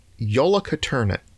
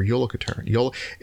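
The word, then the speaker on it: Jólakötturinn: It does start with like a J, but it's Jólakötturin. – Okay. – Or Jólakötturin.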